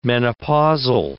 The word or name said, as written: menopausal